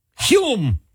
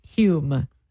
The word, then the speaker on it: Hume: It is pronounced Hume. – Hume. With no L.